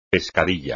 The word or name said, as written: pescadilla